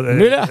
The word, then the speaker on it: Müller: Müller. – Ça s'écrit M-U-E-2-L-E-R.